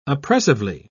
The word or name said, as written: oppressively